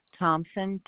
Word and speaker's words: Thomson: Louise, L-O-U-I-S-E, Thompson, T-H-O-M-S-O-N. – L-O-U-I-S-E for Louise, T-H-O-M-A-S. – No, Thompson without a P, T-H-O-M-S-O-N. – T-H-O-M-S-O-N.